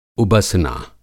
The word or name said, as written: उबसना